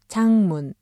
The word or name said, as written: 작문